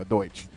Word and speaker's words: Deutsch: The commercial was created by Douche. Okay, I don't know how to say that correctly. It's not Douche. It's like Deutsch. It's Deutsch. There we go, Deutsch. It's not Douche.